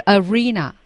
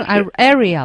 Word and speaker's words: arena: 对，刚才那个地方是 arena 啊，是个场所，什么竞技场啊之类的领域啊等等这些啊，我还读成 area 了，你看看啊。